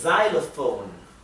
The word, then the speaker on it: xylophone: X for xylophone. Xylophone. ¿Sí? La X en inglés a veces se pronuncia z, como aquí, y a veces se pronuncia x, ¿sí?